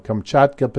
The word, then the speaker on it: Kamchatka: But anyway, this past December, there was a pretty good size one in the region around Kamchatka, Kamchatka, I always say that wrong, Kamchatka Peninsula is the correct pronunciation, in northeastern Russia.